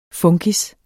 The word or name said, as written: FUNKIS